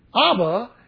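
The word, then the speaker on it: Ab (אב): Abba is an Aramaic word, comes from the Hebrew word, A-B, which you would say, Ab.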